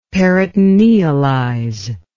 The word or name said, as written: peritonealize